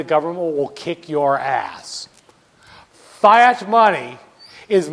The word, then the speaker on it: fiat: So now we get to the final kind of money, fiat money. Fiat money. Fiat money, actually, it's actually pronounced fiat, but no one says it that way.